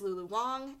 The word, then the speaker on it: Lulu Wang: subconsciously saying Lulu Wang this whole time, and it's Lulu Wang, and I just had, like, this entire conversation with somebody about how, like, pronouncing, like, names correctly.